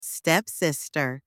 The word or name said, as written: stepsister